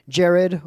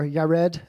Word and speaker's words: Yared: Jared, or Yared, as it's properly pronounced, means shall come down.